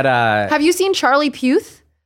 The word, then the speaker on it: Puth: No, it's Puth. – Oh, whatever. Oh, it's spelled the same. – It's P-U-T-H. – Puth.